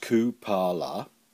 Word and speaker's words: Kupala: Cupola, pronounced Kupala, is the Slavic goddess of water, herbs and magic.